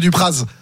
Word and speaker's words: Dupraz: Je pense que droit de réponse, Monsieur Dupraz. – Ouais. Donc vous n'êtes pas savoyard, vous, par contre, ni au savoyard, parce que normalement on ne prononce pas le Z, mais c'est pas grave.